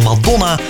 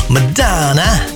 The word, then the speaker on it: Madonna: Die heeft ons met z'n allen geleerd hoe je Madonna moet uitspreken. Dat is niet gewoon Madonna, maar wel Madonna. Je hoort er nog eens Madonna, dus met The Power of Goodbye.